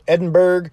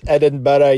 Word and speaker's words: Edinburgh: I'm so-and-so in Edinburgh." And you hear off to the side some Scottish guy go, "It's pronounced Edinburgh, you fucking wank."